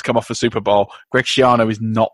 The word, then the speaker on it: Schiano: Yeah, Greg Schiano.